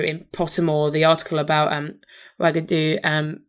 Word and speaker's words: Wagadu: And I've forgotten how to pronounce it, because Pottermore does provide a very nice pronunciation. Wagadu, there, yeah.